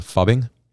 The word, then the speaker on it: phubbing: Have you guys heard of the term fubbing? – What is it? – Fubbing. – No. Spell it. – P-H-U-B-B-I-N-G.